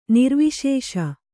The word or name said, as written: ನಿರ್ವಿಶೇಷ